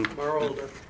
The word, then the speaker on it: Marolda: Marolda. – Can you spell that? Just so that M-A-R-O-L-D-A.